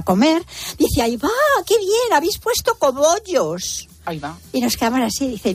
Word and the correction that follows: cogollos: mi cuñada, ay, perdona tía, pero es que no se dice cobollos, se dice cogollos.